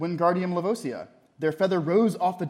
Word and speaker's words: Wingardium Leviosa: You're saying it wrong, Harry heard Hermione snap. It's Wingardium Leviosa. Make the gar nice and long.